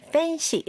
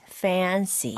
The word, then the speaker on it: fancy: 네, 여러분 발음을 fancy 이렇게 하지 말고 A를 늘어지게 fancy.